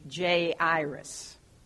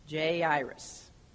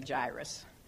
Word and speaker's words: Jairus: Actually, the pronunciation should be Jairus. Jairus. But that's harder to say. I'm going to say Jairus.